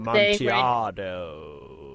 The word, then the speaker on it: Amontillado: I wouldn't want to do like the cask of Amontillado. I think that's how you say the Edgar Allan Poe thing. – Amontillado. – Right? Yeah, that's it.